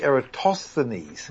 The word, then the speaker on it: Eratosthenes: Well, I'm following the standard English pronunciation where the stress is on the third last syllable, but so it should be Eratosthenes.